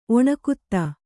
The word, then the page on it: ಒಣಕುತ್ತ: Kannada